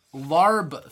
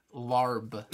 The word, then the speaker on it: Larb: They are larb flavored. – Excuse me? – Larb. – Can you spell that? – L-A-R-B.